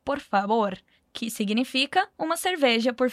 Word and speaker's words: favor: A gente só deve cuidar mesmo com a pronúncia do V, que, mais uma vez, falamos com o som de B no espanhol. Então, repete comigo. Por favor.